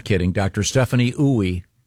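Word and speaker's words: Ooi: Dr. Stephanie Ooey, there's a pronouncer here, and it says it rhymes with gooey.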